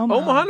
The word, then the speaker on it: Omaha: Omaha, Nebraska? – You got it. 3 syllables.